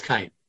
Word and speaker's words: Cain: If you say Lamech argued that I shall be punished by heaven, then I reply that if in the case of Cain, am I saying that wrong? Who was the first murderer? – In Hebrew, it's Cain.